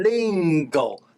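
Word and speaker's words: りんご: 麟禽哦，翻成日文就念成 lingo。所以呢，你会听到很多人念 lingo 是日本话，也是台语呢，因为台语也是从日文来的，所以叫做 lingo。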